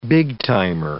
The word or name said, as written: big-timer